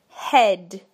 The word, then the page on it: head: British English